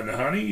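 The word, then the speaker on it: Nahanni: Tonight we're going to be talking about the Nahini Valley of the Headless Men. Did I say that right? – Uh, Nahani, yeah.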